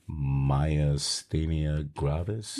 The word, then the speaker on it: Myasthenia Gravis: a disease called Myasthenia Gravis.